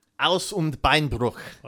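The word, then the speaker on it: Hals- und Beinbruch: Well, then you probably should have said Halsenbeinbruch, because I'm sure I'm not pronouncing that correctly. – Aus und Beinbruch. – Well, see, there you go.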